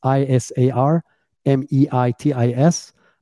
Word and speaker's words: Isar Meitis: Isar Meitis. It's Isar Meitis. I have a really, really different name, so I have to spell it and pronounce it every time, but I'm the only Isar Meitis on LinkedIn.